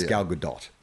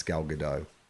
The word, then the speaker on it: Gadot: The Gal Gadot pronunciation. It's Gal Gadot. And we were saying it's Gal Gadot. – Gal Gadot. – Is that right? Yes, it's Gal Gadot.